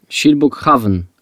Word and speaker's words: Kilbokhavn: Pisze się Kilbokhavn, ale to kil się czyta jak sil.